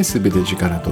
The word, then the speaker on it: 総力: えー、自力全て力の反対は自力なんですね。まあ私がその自力に、えー、っと対抗して自力対抗じゃないか自力の反対の概念として作り出した造語がこの「総力」と書いて「全て力」と読む